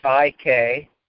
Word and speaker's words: Psy-K: And at the end of the book, the author recommends a process called Psy-K. It's spelled psych with a hyphen and a K on that. But they don't pronounce the in the psych.